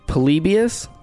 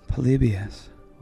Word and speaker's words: Polybius: Polybius.